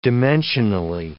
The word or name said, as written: dimensionally